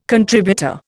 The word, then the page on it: contributor: Indian